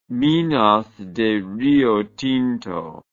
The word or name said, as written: minas de riotinto